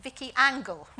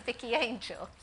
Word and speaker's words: Vicky Angel: And I think 9 out of 10 girls write down, I don't think they pronounce it like this, but Vicky Angle rather than Vicky Angel.